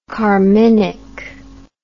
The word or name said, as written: carminic